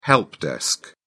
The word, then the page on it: help desk: British English